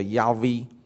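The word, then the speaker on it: 亚 威。 (יהוה): 耶和华这个名字其实就是自有拥有者的意思，它的希伯来的原来的发音叫亚威。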